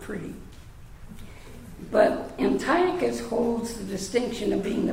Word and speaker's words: Antiochus: The C-H is always a K. So when you look at Antiochus, I don't want to say it wrong, you have a tendency to put a like an English pronunciation on that with a C-H.